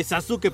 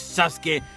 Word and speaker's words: Sasuke: Es que sí, se supone que se escribe Sasuke, pero es Sasuke. Y oigan amigos, tenemos que hablar, ¿eh?